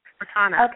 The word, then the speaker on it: Cortana: And they have that thing called, is it Cortana? Is that how you pronounce yes. – it? – Cortana.